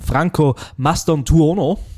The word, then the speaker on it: Franco Mastantuono: Den, und das muss ich richtig aussprechen, Franco Mastantuono.